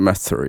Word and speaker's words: Mather: Den är gjord av tre brittiska forskare som heter Rachel Marcus, Druva Matur och Mather kanske. Vad säger du? Hur uttalar han sitt efternamn? – Mm, Mather, ja. ja.